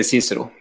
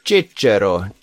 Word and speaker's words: CICERO: Cicero. Uttaler jeg det riktig? Jeg bruker å si Cicero, men ingen er sikker siden det er latinsk og ingen som snakker det, lever lenger. Cicero.